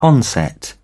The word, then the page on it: onset: British English